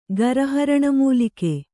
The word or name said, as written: ಗರಹರಣಮೂಲಿಕೆ